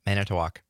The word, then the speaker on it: Manitowoc: Yeah, Manitowoc.